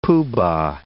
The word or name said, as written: poo-bah